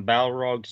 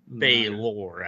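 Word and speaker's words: Balor: The lizard folk worship Orcus, and the Balrog serves Orcus. – We have like a friggin' Balrog and a Yonti. – Well, it's Baylor, I guess. Baylor. Is how they say it in D&D.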